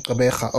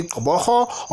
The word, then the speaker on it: Gqeberha: He says, I'm hoping that people of the EC will take this opportunity to make isiXhosa the main language used in Gqeberha, or else people must take classes, Sitikiwe, to be treated like guests in our land.